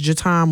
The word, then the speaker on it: Jataun: My Facebook is Jatan Morgan. – Spell that. – J-A-T-A-U-N, last name Morgan, M-O-R-G-A-N.